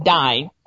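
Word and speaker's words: Diné: Now, Navajo Indian language, also known as Dine, and that's how it's pronounced, Dine, Navajo, known to its own speakers as Dine, is an Athabascan language of the American Southwest.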